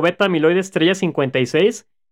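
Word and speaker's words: beta amiloide estrella 56 (Aβ*56): En 2006, Sylvain Lesne publicó en Nature un estudio que demostraba que el oligómero beta amiloide estrella 56, así se pronuncia aunque se escribe con asterisco,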